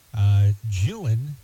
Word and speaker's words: Gillan: Karen Gillan, am I saying that right? G-I-L-L-A-N, the Scottish actress, has played Nebula in 6 of the Marvel Cinematic Universe, Guardians of the Galaxy, Avenger, and Thor.